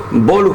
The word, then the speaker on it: Boluspor: En la segunda división de ahí de Turquía, profe, en el Boluspor, puede ser, es la pronunciación aquí para nosotros, Boluspor.